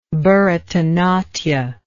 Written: Bharatanatya

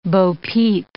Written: bopeep

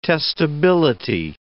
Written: testability

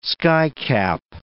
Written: skycap

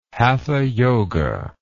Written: hatha yoga

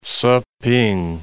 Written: Siping

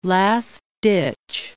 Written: last-ditch

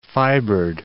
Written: fibered

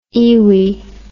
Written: iwi

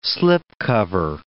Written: slipcover

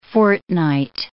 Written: fortnight

